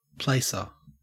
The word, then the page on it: placer: Australian English